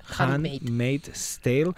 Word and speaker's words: Handmaid's Tale: The Handmaid's Tale, no ho he pronunciat bé. Handmaid's Tale.